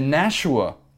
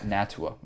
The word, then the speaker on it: Nashua: So in the Nashua, not Natua, Marler. In the Nashua, I was pronouncing the T as an SH, to my defense. – It's like a ch.